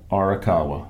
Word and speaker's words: Arakawa: Arakawa.